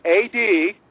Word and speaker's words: ad valorem: It's ADVALOREM.